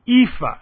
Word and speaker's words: EAFE: And the same thing is true for the EAFE, and that's how it's pronounced, don't ask me why, Europe, Australia, and the Far East.